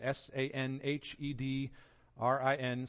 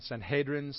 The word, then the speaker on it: Sanhedrin: Now, now, now Mark has just named all three parts of what we call the Sanhedrin, S A N H E D R I N, Sanhedrin, Sanhedrin, however you want to pronounce it.